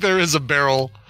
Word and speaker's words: Beryl: Fast food chain Whataburger app had gone viral in the wake of the Hurricane Beryl. Is that how we're pronouncing that? – Yeah. – Okay. – Yeah, it's Beryl. Yep.